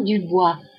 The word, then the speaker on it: Dubois: Je m'appelle Isabelle Dubois. Mon prénom, c'est Isabelle et mon nom Dubois. Ça s'écrit D-U-B-O-I-S.